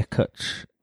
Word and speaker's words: cwtch: Yeah, Cutch, which is spelled CWTCH. And it's just the Welsh word for, like, a cuddle or a hug.